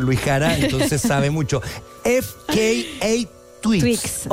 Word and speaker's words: FKA Twigs: Por la segunda figura, que no tengo claro cómo se pronuncia, pero ustedes me ayudarán en su angloparlante perfecto. FKA Twigs. – Así es. – Twigs. – FKA Twigs.